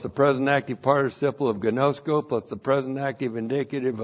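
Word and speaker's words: ginosko: And then we have gnosko. G-I-N-O-S-K, long O. Gnosko.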